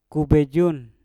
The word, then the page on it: ku bejung: Mpur